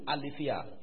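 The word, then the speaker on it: aletheia (ἀλήθεια): The word truth is from the word aletheia, from alethes. Aletheia is spelled A-L-E, is a word we all know. T-H-E-I-A, aletheia. You check your concordances, you find alethes is from alethes, A-L-E-T-H-E-S.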